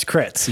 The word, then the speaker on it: crits: And then two crips. – God. No, that's crits. You said that wrong.